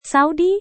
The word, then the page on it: saudi: indonesian